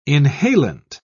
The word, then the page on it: inhalant: British English